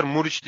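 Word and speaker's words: Muriqi: Adamın adını Q'da yazıyorlar. Muriç diye okunuyor. Ya dünyada hangi alfabede Q Q'la Ç sesi veriyorlar?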